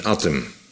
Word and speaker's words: Hattem: Dat ze daar de H niet uitspreken. Dus die mensen wonen daar in Attem.